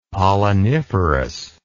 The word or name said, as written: polliniferous